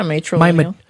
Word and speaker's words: matrilineal: My matrilineal lineage. How do you say that? – Yeah, matrilineal.